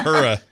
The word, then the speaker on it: Uhura: Also, how do you pronounce her name properly? – Not the way I just Uhura? – Did it. Yeah, Uhura. – You know, half point for getting that right.